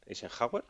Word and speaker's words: Eisenhower: Y en cuanto a las tareas, a la hora de clasificarlas, pues bueno, aquí el señor Eisenhower, ¿lo he dicho bien? – Sí, señor.